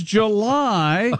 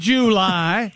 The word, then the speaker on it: July: Just get the second syllable out. That's all that. – Calm down. – You know what? Yes, it can be July if you don't spend so much time on the first syllable.